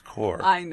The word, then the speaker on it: corps: No, that's pronounced core. – I know, but it's spelled that way.